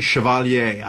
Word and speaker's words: Chevalier: And I pronounce it the French way, Chevalier. I don't know if it's Chevalier, like the way, you know, but think about Chevrolet.